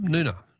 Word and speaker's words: Noonga: Noonga is pronounced with a silent G. Is it? Oh, thanks, Barb. Noonga.